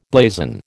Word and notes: blazon: American English

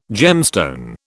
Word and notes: gemstone: British English

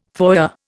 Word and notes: foyer: Indian

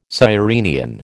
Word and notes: sirenian: American English

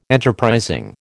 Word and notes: enterprising: American English

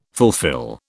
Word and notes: fulfil: British English

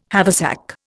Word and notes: haversack: Indian

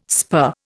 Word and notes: spur: Indian

